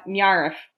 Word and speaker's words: NIIRYF: And the hashtag that we follow is NIIRYF, and that is pronounced Nyarf. There.